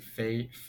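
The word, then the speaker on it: Feyd: It's F E Y D, so it's like Fade Fade.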